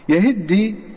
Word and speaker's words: يهدي: يهدي، يلاحظ في هذه الكلمة أن التاء غير موجودة، أصلها يهتدي فهذه التاء غير موجودة، وإنما التاء أضغمت في حرف الدال فلم تلفظ التاء،